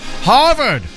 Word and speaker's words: Harvard: 384 years ago today, 1642, Harvard. You really have to put some emphasis on the H there.